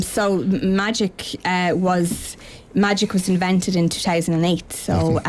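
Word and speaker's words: MAGEC: It is pronounced magic, but it's magnetic controllable rod. – Right. – So it's just magic.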